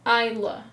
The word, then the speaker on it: Isla: I'm going to look up how to say her first name. – ISLA. Like, how would you say that? – But she's not American. – I don't look up the backgrounds on these people. – Isla? – Scottish. Huh. Again, my apologies. I've been saying your name wrong my entire life.